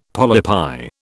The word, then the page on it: polypi: British English